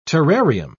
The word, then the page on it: terrarium: British English